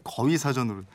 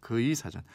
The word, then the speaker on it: 그의 사전: 그의 사전이 거위 사전으로 이런 질문 보내주셨는데 일단 사전 이름을 다시 정확히 말씀드리면 그건 이렇습니다. 앞글자 따서 그의 사전입니다. 그의 사전. 부의 사전 아니고 거의 사전 아닙니다.